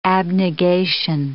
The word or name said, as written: abnegation